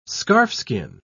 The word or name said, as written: scarfskin